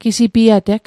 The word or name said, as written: gisipiateg